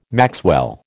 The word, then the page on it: maxwell: American English